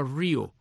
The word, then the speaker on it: REO: Y este señor pues funda esta compañía de camiones que se llamaba así REO, con la diferencia de que la banda de rock and roll dice REO, o sea, R-E-O, deletrea, mientras que el camión se pronunciaba Rio, o sea, R-E-O, Rio Speedwagon,